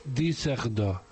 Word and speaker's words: Düsseldorf: Es que Düsseldorf lleva la Düsseldorf, que se escribe, lleva dos puntos en la U. Y entonces dice Düsseldorf.